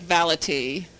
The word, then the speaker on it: Valatie: V-A-L-A-T-I-E, so V like Valentine, A like apple, L like lemon, A, T like toy, I-E. It sounds like Valaty.